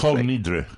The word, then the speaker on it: Kol Nidre: Kol Nidre.